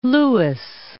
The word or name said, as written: Port Louis